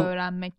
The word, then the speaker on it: öğrenmek: So, öğrenmek. – Right. This Actually, this strange, like the G sound, soft G, we say. It's actually nothing. It just lengthens the vowel.